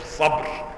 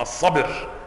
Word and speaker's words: الصبر: وقلوبهم أمر من الصبر، مش الصبر، أنا نطقتها في الأول خطأ، الصبر بكسر الباء،